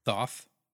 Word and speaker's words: Thoth: Thoth.